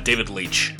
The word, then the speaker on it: Leitch: David Leitch.